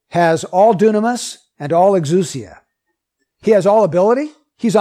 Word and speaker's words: exousia: One is dunamis and one is exousia. Exousia, you'd spell it in English, E-X-O-U-S-I-A.